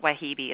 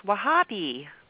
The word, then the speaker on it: Wahhabi: It's not Wahhabi, it's Wahhabi.